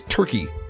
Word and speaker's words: Turkie: The villain's name is Turkey, but it's spelled T-U-R-K-I-E.